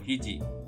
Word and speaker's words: 肘: 这边有一个膝盖的膝，念作膝元啊，另外手肘的肘念作 hiji 啊，手肘。